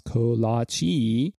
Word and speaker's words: kolache: The word kolache, pronounced kolache, and also spelled kolace, kolach, or Kalake, Kalake, I can't say it, for me, forgive me.